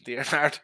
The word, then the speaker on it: D'Arnaud: Travis D'Arnaudide. Right? Is that how you pronounce his name? D'Arnaud. Travis D'Arnaud. We have to go French style, like Talion.